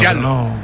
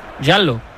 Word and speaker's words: giallo: Giallo, ¿no? Giallo. – Giallo.